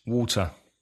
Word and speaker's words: water: So the T in some American English pronunciation becomes like a D yep. – sound, doesn't it? – Yeah. And there are other words like that, like party, water, party. The T sounds like a D. – Better. – Better. – Writing. – Right. – Writing. – Okay, sorry. Writing as in I'm writing a letter. I'm writing a letter, yeah. – And bottom. – Bottom. – Yeah. – Bottom, yeah. That T being converted to a D sound.